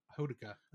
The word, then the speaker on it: Hodaka: So Honoka is, am I pronouncing that? Honoka? – Hodaka. Hodaka. How I've been pronouncing.